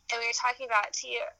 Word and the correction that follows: Thea: Or Thea, that's how you say her name, like, playing those characters, and I was like, I could totally, like, be the AJ Lee in that movie.